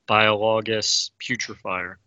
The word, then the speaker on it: Biologus Putrefier: Okay, let's scroll real quick down to the, I'll butcher this, but the Putrefier, Biologus Putrefier, right? – Well done. – Yep.